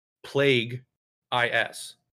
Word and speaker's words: Plagueis: Which, by the way, I watched some YouTube videos about Darth Plagueis, and his name is spelled literally Plague IS. It's Plagueis.